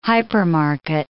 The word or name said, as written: hypermarket